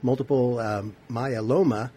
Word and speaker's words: multiple myeloma: Now, we've talked about your tonsorial expertise before, but today I'd like to talk about multiple myeloma and your involvement in the Moving Mountains for Multiple Myeloma. I never heard of multiple myeloma. Am I pronouncing that correctly, by the way? – Multiple myeloma.